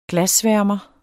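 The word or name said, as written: GLASSVÆRMERE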